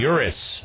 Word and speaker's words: Urus: The Lamborghini Urus. I guess that's how you pronounce it. U-R-U-S is how it's spelled.